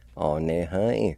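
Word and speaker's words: anehin: Number three, citizens, onehai.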